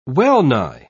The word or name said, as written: well-nigh